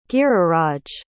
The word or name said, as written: giriraj